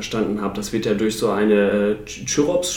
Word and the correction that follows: Chrysops: spricht man, oder Chirsops? Oder Chrysops. Chrysops, ja, ich denke.